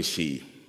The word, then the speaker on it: chi: Vi brukar ju tala som XP i våra sammanhang. Och det är inte riktigt rätt. Vi uttalar dem på svenska. Chi och rå heter de på grekiska.